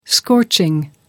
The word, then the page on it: scorching: American English